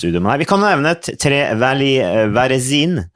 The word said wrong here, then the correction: Varesine: Er det ikke det sånn det uttales? Varesine? – Tre eh? Valli Varesine. – Ja, Varesine, ja, ikke mm.